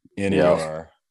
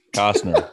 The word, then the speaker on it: Costner: I just like Brant again saying Costner, COZZNER. That's like Clemson. – No. – That's Costner. How you say it, right? – 100%. Costner. That's it.